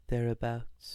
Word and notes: thereabouts: American English

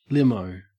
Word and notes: limo: Australian English

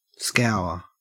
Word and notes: scour: Australian English

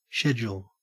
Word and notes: schedule: Australian English

